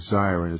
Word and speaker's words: Zirin: Well, as you said, I mean, one of the things, and we're with Dave Zirin, is that how I pronounce it? – Yeah, like a fire siren.